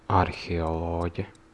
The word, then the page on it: archéologue: /aʁ.ke.ɔ.lɔɡ/